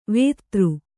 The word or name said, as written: ವೇದ